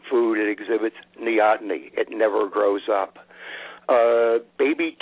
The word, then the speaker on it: neoteny: Well, one other question I have, and I hope I'm pronouncing this right. What is neoteny? – Neoteny. – I'm glad. Yes, thank you.